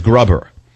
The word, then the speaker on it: Gruber: You can pronounce Gruber as Grubber.